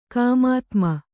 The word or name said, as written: ಕಾಮಾತ್ಮ